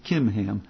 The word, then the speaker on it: Chimham: But here's your servant Chimham. Now some people pronounce it Kimham. Chimham, let him go over with my lord the king and do whatever seems good to you.